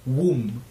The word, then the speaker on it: womb: ¿qué otra palabra se escribe así que se dice Tomb? Womb, que es útero.